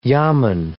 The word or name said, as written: yamen